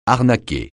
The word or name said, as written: arnaquer